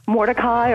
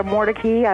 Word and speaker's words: Mordecai: And just like Mary Turk and Mordecai or Mordecai, I've heard it pronounced both ways, she goes to visit another place I wrote about, the Blount Bridgers House.